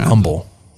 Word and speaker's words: Humble: I believe it's Humble, like without the H.